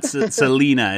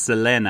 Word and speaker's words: Selena: let's get the pronunciation right there correct on Selena. And I think it's not Selena, it's Selena, I believe, is how you're supposed to pronounce it, Nathan.